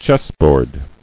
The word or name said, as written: Chessboard